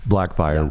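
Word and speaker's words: Blackfire: is it Blackfyre? – Yes. – Blackfyre, yeah. Which is Starfire's sister.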